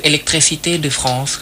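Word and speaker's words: électricité de France: electricité de France ครับ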